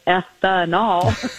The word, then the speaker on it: ethanol: And as you said, I love how you said it's three syllables, ethanol.